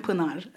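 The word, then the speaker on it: Pınar: I say Pinar. That is not her name. Can you say it? I would like you to say it correctly. – Hello, I am Pinar.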